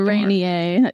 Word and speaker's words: Rainier: A Rainier, yes.